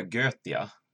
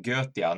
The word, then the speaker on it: Goetia: Jag har ju spelat det här, uh, hur det nu ska uttalas, men som jag vill minnas att det stod i regelboken så skulle man säga Götia. Goetia stavas det ju, men Götia, Nine Kings of Solomon.